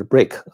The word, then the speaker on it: BRIC: 巴西曾经是一个非常繁荣的经济体啊，就是当时有一个说法叫“ 金砖四国” 嘛，呃，那个叫 BRIC 嘛，就是 Break 啊，就是有点这个发音像砖头。